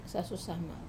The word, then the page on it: sasu sama: Wamesa